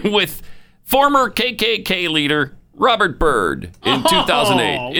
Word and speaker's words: Robert Byrd: There he is, holding hands with Robert Byrd.